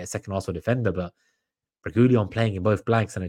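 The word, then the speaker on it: Reguilón: Yeah, if we're saying like nobody's going to keep a clean sheet, then Reguilon, that the U is silent, by the way.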